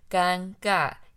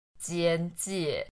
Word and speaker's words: 尴尬: 所以“ 尴尬” 这个词，因为它的汉字长得有点难，但你可以看出它上面有个“ 间” 跟“ 介” 这两个字，所以在台湾，有些人即使知道它念作“ 尴尬”，也会故意像刚才这念的“ 间介”，虽然“ 间介” 不是正确的念法啦。